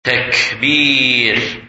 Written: Takbir

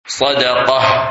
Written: Sadaqah

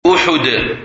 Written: Uhud

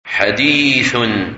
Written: Hadith

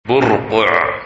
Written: Burqa

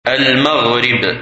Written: Maghrib

